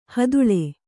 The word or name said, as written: ಹುದಲು